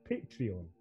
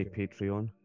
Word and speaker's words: Patreon: Is it Patreon. How do you pronounce that? – I say Patreon. Do you? Or do I say Patreon? Patreon.